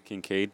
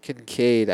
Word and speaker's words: Kincaid: Kincaid. – Kincaid.